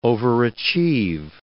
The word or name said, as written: overachieve